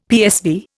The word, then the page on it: PSV: Indian